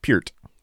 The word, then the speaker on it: Peart: Neil Peart or John Bonham? Peart. – Oh. – Say it right. – That's Peart. Sorry, Peart.